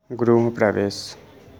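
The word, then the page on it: Grihapravesh: Gujarati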